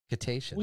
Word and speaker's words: cetaceans: So Cetaceans. – There you go. Cetaceans.